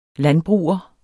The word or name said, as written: landbruger